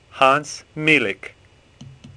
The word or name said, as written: Mielich